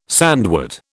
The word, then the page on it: sandwort: British English